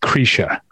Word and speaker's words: Krisha: I think it's pronounced Krisha. – Krisha. Okay. – It's an I, but it's like an E sound. Krisha.